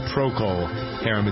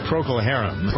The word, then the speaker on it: Procol Harum: Some people don't know how to pronounce that. It's not Procol Harum. It's Procol Harum.